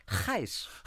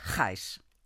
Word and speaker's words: Ghys: Buchstabieren tut man es G-H-I-J. – Y. – Ah, G-H-Y-S. – Y-S. – G-H-Y-S. – Und das ist ein belgischer Name, frag mich. Geis. Wiske Geis. – Und in Vorbereitung auf das Gespräch merkte ich, dass die Geis, wie man über Schauspielern sagt, die Geis.